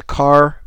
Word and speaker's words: Kahr: So the first one that I bought was a Carr P380, a Carr Arms. For those of you who don't know, K-A-H-R is how that's spelled.